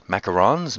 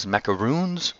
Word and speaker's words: macarons: And that's where they sell macarons. Macarons? Macaroons? Am I saying this correctly? Macarons.